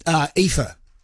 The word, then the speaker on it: Aoife: So Aoife, A-O-E-F-I-A or something like that, Aoife. And that's his wife's name. And my daughter went to school with a girl called Aoife, hence I know the name and how to pronounce it.